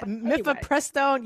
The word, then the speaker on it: Mifepristone: A judge in Amarillo, Texas just took one step closer to making Mephistoprode. I always mispronounce it. Forgive me. – Same. – Mephistoprode, I think it's called. – Yeah, Mifepristone.